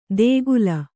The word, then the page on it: ದೇಗುಲ: Kannada